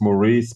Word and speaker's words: Moreeze: It's Maurice, spelled with M-O-R-E-E-Z-E.